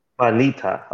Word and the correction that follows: banitsa: Okay. – Banitsa. Yes.